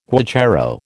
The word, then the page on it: guacharo: American English